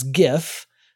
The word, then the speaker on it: GIF: So obviously I still pronounce GIF as GIF. I do not pronounce it as GIF.